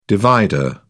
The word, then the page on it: divider: British English